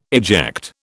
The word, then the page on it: eject: British English